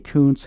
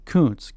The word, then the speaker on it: Kuntz: we all remember Rusty Kuntz, K-U-N-T-Z, and the numerous unfortunate times Rusty Kuntz's nickname was mispronounced into something that rhymes with dunce.